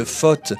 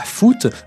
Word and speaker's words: foot: Si on regarde à présent la même carte, mais où on a représenté des témoins qui ont affirmé qu'ils ne disaient que faute et pas foot, le changement de physionomie est total.